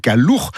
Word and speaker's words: Calour: Alors ça s'écrit Calour, mais on ne prononce pas le R final.